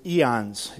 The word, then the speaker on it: aeon: This word eternal is the word, we get the word eons. You guys ever use that word eons and eons ago or eons? It's Greek. It's aeon, or pronounced eon, and it's the same word used for both destinations.